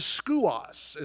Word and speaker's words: Sceva: Now, when you look at the text, the English text, it looks like the names are Sceva, but it's actually Skuas is how it's pronounced.